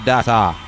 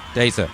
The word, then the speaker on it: Data: He scans his eyes similar to Data. Data. He's called Data. Not Data, but Data is how Americans pronounce Data.